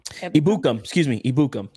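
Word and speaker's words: Ebukam: The guy who keeps getting in my head, I keep forgetting about him, Samson Ekubon. I think I say it right. Ibukam. That's how you say it.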